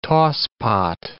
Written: tosspot

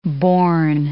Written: bourne

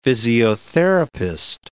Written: physiotherapist

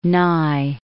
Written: Nye

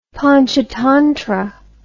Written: Panchatantra

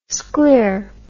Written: scler

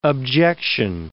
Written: objection

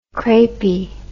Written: crepy

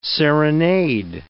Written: serenade

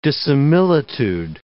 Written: dissimilitude

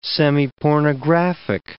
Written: semipornographic

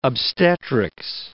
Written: obstetrics

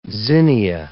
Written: zinnia

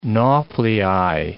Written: nauplii

